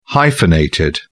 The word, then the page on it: hyphenated: British English